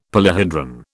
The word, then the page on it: polyhedron: British English